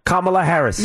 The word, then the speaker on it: Kamala Harris: The person who ran against Donald Trump for president this year, her name was Kamala Harris. – You said it correctly.